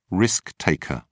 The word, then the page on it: risk taker: British English